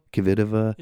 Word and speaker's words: Kvitova: And she's so tall that even, I mean, Kvitova, how do you say her? – Kvitova.